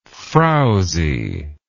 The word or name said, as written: frowziness